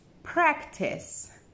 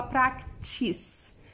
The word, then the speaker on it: practice: Practice. Presta atenção no C e no final. Você não fala practice. É practice. Test muito importante esse finalzinho.